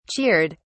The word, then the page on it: cheered: British English